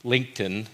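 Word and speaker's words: lencten: And this Lent season, Lent for short, Lenten, it really comes from an old Anglo-Saxon word, Lincton is how you say it, but it represents or refers to the season of spring.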